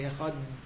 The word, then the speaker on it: Echad: Sumchus taught us that when we say Shema, we should elongate the Dalit of Echad. We should say Echad. Now, the way we pronounce the Dalit, that's impossible. But the way the Yemenites do, like a TH, then you could say it longer.